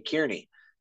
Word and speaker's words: Kearney: I honestly couldn't even pronounce it right because I used to say Kearney because it's K E A R N E Y, but they're like, no, it's Kearney, and I'm like, that's not how you spell it.